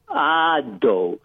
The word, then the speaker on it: veados: Okay, well, anyway, so the word for deer in Portuguese is veados, V-E-A-D-O-S. – Okay. – But I mispronounced the word as V-I-A-D-O-S.